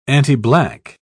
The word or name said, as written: antiblack